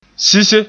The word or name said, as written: sise